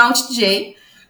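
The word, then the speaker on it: alt-J: Inclusive, eu escuto mais música na TV do que assisto a TV, que é o Alt chama Alt J, mas se escreve Alt J, como se fosse o atalho do teclado.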